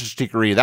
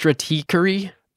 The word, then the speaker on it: Stratechery: So it's strategy and tech. And yes, there was the Stratechery bit. And so then I'm like, well, I should pronounce Stratechery.